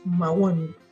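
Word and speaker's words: Mawün: Con él vamos a hablar sobre el explorador de precipitaciones Mahón. ¿Está bien dicho, Mauricio? Bienvenido. – Sí. Hola, buenas tardes. Está bastante bien pronunciado.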